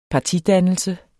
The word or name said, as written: partidannelse